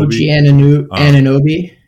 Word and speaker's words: Anunoby: OG Anunoby.